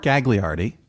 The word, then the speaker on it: Gagliardi: So, first now, I should clarify, it's pronounced Mark Gagliardi, and we had him on. No, that's fine. I mean, you were pronouncing it in the proper Italian way, you know what I mean?